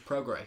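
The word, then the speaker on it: Prograis: First one we have is Pergray. Or Pergrace. However you pronounce it. – Regis Progray. – Yeah, that's it.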